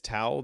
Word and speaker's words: tau: So are you saying tau like T-O-W? – It's tau, the Greek letter, T-A-U is how spell it when we write it out.